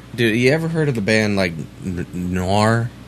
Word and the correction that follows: Gwar: Noir? – You want to spell that one for me? – I think it's G-W-A-R or something like that. – Oh, Gwar. – Gwar.